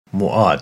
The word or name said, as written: Moaad